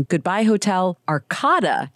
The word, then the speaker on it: Goodbye Hotel Arkada: Congratulations on the new album, Goodbye Hotel Arkada, you just told me is how it's pronounced.